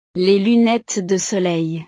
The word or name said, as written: les lunnetes de soleil